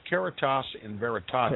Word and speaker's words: Caritas in Veritate: It's pronounced Caritas in Veritate, okay, which means charity and truth.